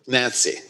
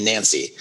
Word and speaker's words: Gyantse: Okay, but it's spelled Gyantse, and you pronounce it almost like the lady's name Nancy. – Yeah, with a G-Y in front of it.